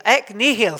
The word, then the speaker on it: ex nihilo: Is that, did I pronounce it right? Nihil, ek nihil, thank you.